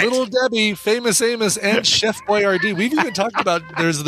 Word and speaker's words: Boyardee: Because we discovered his name is pronounced Chef Boyardee.